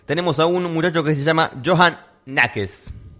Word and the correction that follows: Neskens: Me ayudan con la denominación del apellido, porque capaz que lo dije mal. Neskens. Johan Nekes. – Neskens. – ¿Cómo es? – Neskens. – Neskens.